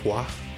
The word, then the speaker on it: Boise: Boise. – Boise will be next. – It's pronounced Bois. – Bois? Yeah. – Bois. – Bois? French.